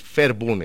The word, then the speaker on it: Verbund: Doctor, ¿qué es el modelo de producción integrada Verbund? ¿Lo pronuncié bien? – Bueno, yo tampoco lo he logrado pronunciar porque es una palabra en alemán y yo no hablo alemán. Pero sí, es el Verbund, es un modelo de producción integrada, correcto.